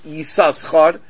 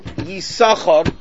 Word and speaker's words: Yisachar: So really it should be pronounced Yisachar. But we don't pronounce it Yisachar, we pronounce it Yisachar, the second shin is silent.